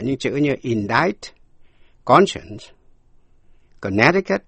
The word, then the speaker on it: Connecticut: Sau đây, âm C câm. Connecticut. Connecticut là tên tiểu bang, chữ âm C câm. Quý vị nghe lại, to connect là nối lại, liên hệ, to connect, âm C có phát âm, connect, nhưng Connecticut, quý vị nghe lại, connect, Connecticut. Và một từ ngữ nữa, Tucson, T-U-C-S-O-N, Tucson, T-U-C-S-O-N.